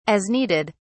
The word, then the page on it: as needed: British English